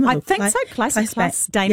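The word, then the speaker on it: Claes Bang: It stars, I think it's Kleiss, is that how you say it? – Kleiss Bang.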